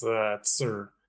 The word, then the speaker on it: Tzur: Yeah, it's Tzur. It's Tzur. Almost okay. like Sir, but with a T.